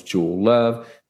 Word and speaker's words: Jewel Love: My name is Jewel Love, J-E-W-E-L-L-O-V-E.